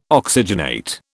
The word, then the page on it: oxygenate: British English